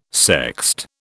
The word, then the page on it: sext: British English